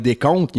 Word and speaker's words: décompte: Mais tu sais, mettons, pour compter, faire le décompte, il y a un P qu'on prononce pas.